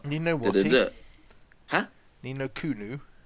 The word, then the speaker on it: Ni no Kuni: Ni No Kuni, Persona, Ni No Kuni. – It's spelled Kuni though. – Yeah, so why do you keep saying it Kuno? – I don't know.